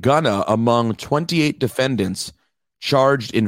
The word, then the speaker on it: Gunna: To say his name right. It's Gunna.